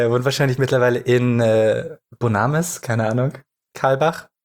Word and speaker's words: Bonames: Aber man spricht es Bonames wohl aus.